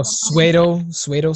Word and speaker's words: pseudo: Oh, pseudo science? – Is that how you pronounce it? – Yeah, it's with a P S U E D.